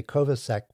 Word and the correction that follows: Kovacevic: but I know I'm pronouncing his name wrong. So I apologize to James again. But the Kovacevic. That's it. – Uh-huh. – I'm starting to get it. Kovacevic.